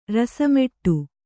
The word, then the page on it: ರಸಮಟ್ಟ: rasa maṭṭa